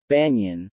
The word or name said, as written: banyan